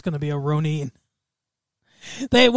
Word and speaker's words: Ronin: I'm going to go with Ronan. – No. – Or I guess Ronin is a proper pronunciation.